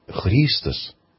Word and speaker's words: Христос: Имя Христос, ударение именно на первом слоге, означает на греческом «полезный», «добрый». Не следует путать с именем Христос, помазанник.